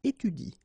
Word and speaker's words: étudie: So in that case, if you want to pronounce it correctly, you should pronounce it like il étudie. Okay, so the last thing that you will pronounce will be the vowel I here, okay, il étudie. You don't pronounce this here.